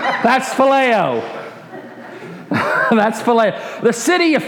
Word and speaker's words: phileo: Pronounced phileo, that's the verb form of it.